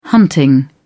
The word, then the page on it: hunting: British English